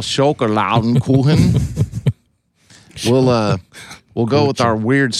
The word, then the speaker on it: Schokoladenkuchen: Schokoladenkuchen. Oh yes. – Schokoladenkuchen. No, it's Schokoladenkuchen. – There's an O in it? – Yeah. Yes. Two O's.